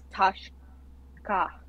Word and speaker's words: Tashka: Okay, T-A-S-H. Do you have a nickname? Because that's going to be common. – Tashka. – Okay, spell that. – T-A-S-H-K-A. – Okay, T-A-S-H-K-A.